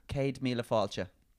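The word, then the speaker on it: céad míle fáilte: Pronounce it like this. Cade. K-A-D-E. Cade. – Cade. – Mila. M-I-L-A. – Mila. Kunis. Yeah. – Yeah. – Cade Mila Kunis. – Falcha. – Falcha. – Yeah. Cade Mila Falcha.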